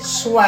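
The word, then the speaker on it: Schweppes: Ginger ale, is it Schweeps or Schweps? How do I say it? – Schweps.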